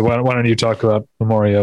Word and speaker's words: Memoria: I've heard it pronounced Memoria by, I would think, people who would know.